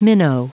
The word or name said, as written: minnow